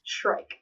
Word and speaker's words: Shrike: Shrike. Mm-hmm. Shrike. Very good. That's a hard I, and I got it.